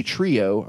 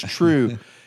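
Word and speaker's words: Trieu: When he was doing this episode 4 rundown, he kept saying Lady Trio. I'm like, no one calls her that. It's True. Everyone says Lady True.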